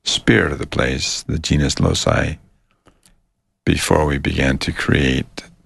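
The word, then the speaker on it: genius loci: And having done that, you tune into the spirit of the place, or what some people call the genus loci.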